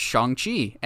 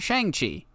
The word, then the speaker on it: Shang-Chi: which it's actually pronounced Shang-Chi and not Shang-Chi.